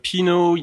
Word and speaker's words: Pineau: Well, it's Pinot. You spell that P-I-N-E-A-U, Pinot de Charente. – Okay, so not like Pinot Noir.